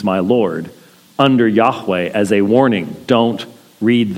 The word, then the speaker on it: Yahweh: that's the English Bible translator's way of saying that's Yahweh, or Jehovah as we slaughter it in English. It could never have been pronounced Jehovah. Jehovah has too many syllables in it.